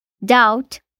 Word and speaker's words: doubt: Quando ela aparece antes da letra T, também fica silenciosa, como em doubt, dúvida, debt, dívida e subtle, sutil.